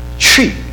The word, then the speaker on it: Twi: Though many Ghanaians speak English, the native language in the Ashanti region is the Tree language. It's pronounced T-R-W-E-E, and in a way that I probably can't do it exactly perfect, but it's spelled T-W-I. Tree, tree.